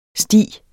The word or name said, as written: sti